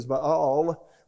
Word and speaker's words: Baal: And last week we talked about Baal, or actually the correct pronunciation, I think, is Baal worship, which Baal worship is worshiped with sexual immorality.